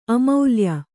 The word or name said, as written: ಅಮೌಲ್ಯ